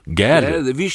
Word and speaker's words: Gade: Königstochter von Nils Wilhelm Gade oder Gorde, glaube ich, ausgesprochen. – Gade, wie Straße.